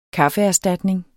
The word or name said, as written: kaffeerstatning